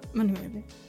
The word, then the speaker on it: Manuele: And he's like, it's fine if you say Manuel. I'm like, no. No, it's not. – So how do you actually pronounce it? – Manuele. – Manuele.